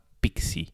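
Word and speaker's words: Pixsy: El servicio se llama Pixi. Pixi se escribe P y latina X S Y.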